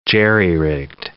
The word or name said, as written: jerry-rigged